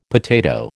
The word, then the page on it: potato: American English